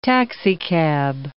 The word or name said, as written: taxicab